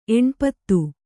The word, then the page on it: ಎಣ್ಪತ್ತು: kannada